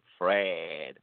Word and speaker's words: fred: Like you say Fred, but you pronounce the E a little bit longer. Fred.